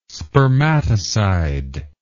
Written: spermaticide

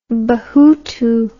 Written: Bahutu

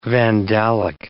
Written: Vandalic

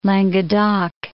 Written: Languedoc

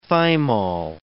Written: thymol

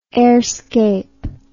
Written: airscape

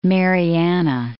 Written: Northern Mariana Islands

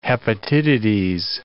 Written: hepatitides

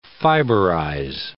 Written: fiberize

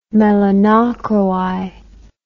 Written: melanochroi

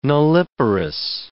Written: nulliparous

